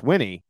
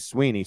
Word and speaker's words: Swinney: It's Swinney, but a lot of people say Sweeney. Sweeney, as you know, Steve, is spelt with an E.